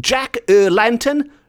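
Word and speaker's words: Jack y Lantern: The O is replaced with a Y, and in Welsh, Y means the, and lantern is spelt as lantern is spelt in English. So we end up with a name which is Jack the Lantern. Jack er Lantern.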